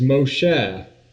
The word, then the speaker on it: Moshe: The word Moses, of course, is the English way of pronouncing the Greek way of pronouncing the actual Hebrew word. The original name is Moshe, and she chooses it because out of the water she drew him up.